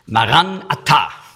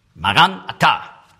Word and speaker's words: Maranatha: Ich habe so gelesen, wie meistens auf Deutsch gelesen wird, Maranatha. Aramäisch würde man aussprechen, Maranatha. Maranatha. Maran, der Herr, Atta, kommt.